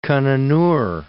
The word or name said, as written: Kananur